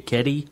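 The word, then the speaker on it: Kedi: I don't know how to pronounce it. K-E-D-I. K-E-D-I. Keddy.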